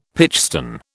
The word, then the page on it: pitchstone: British English